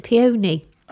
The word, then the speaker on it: peony: I know, not as bad as some other animals on the farm, but luscious cherry and pony. – It's peony, Mark. You pronounce it peony.